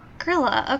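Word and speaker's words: Grýla: So, have you ever heard of Grilla? – No. How is it spelled? – G-R-Y with an accent to indicate that it's a short I sound, L-A. – Huh. Grilla, okay.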